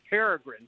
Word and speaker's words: peregrine: But anyhow, we were standing out in the yard, and she saw a bird fly over, and she said, "Look, it's a peregrine falcon." And she pronounced it like that. So we're little kids look up there saying, "How can she see two green birds all the way up there?" And we just about, we did not laugh because she'd have walked this one. But because she mispronounced it. It was years before I realized it was peregrine.